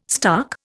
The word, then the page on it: stark: Indian